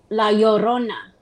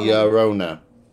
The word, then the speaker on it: Llorona: La Llorona. – La Llorona. – La Llorona. – No, it's called the Weeping Woman. Weeping, crying. – If you roll your L's, Llorona.